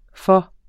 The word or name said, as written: for